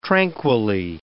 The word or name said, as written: tranquilly